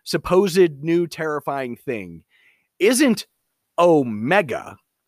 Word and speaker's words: Omega: But Omega is the long O sound, so it's Omega.